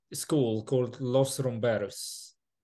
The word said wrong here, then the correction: Los Rumberos: Is it the right pronunciation for the name first? – Los Rumberos, yes.